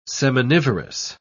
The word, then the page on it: seminivorous: British English